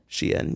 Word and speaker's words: Shein: Oh, is that how you pronounce it? – Shein, yeah.